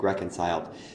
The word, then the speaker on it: reconciled: So be careful with these three-syllable words like reconciled, because the three-syllable word only has one stress point. The word reconciled, the stress point is the first syllable.